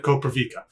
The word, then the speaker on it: Koprivica: And he said Koprivica. It's pizza. Like pizza.